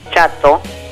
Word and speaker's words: Txato: Bueno, la novela esta empieza justamente el día en que se entera Vittori, como se llama la viuda del chato, que todo esto yo les digo así, pero se escribe T-X-A-T-O.